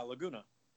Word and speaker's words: Laguna: Yeah, Laguna.